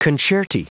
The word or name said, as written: concerti